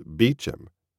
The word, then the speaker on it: Beauchamp: spelled it, B-E-A-U-C-H-A-M-P, pronounced Beecham, told him the story of that day, his birthday.